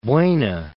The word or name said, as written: Buena Park